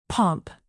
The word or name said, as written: pomp